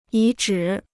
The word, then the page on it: 遗址: yí zhǐ